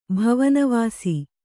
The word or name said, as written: ಭುವನವಾಸ